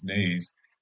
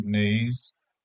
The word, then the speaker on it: Naese: Before we leave the article, the family or the couple that was on the motorcycle, Mr. and Mrs. Nays, and their last name is spelled NAESE. So that's Lester Nayes and Greta Nayes.